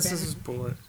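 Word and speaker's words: Bulleit: Well, no, it's spelled weird, but it's also from Kentucky, so I'm pretty sure it's not Boulay.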